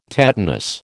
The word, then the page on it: tetanus: American English